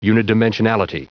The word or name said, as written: unidimensionality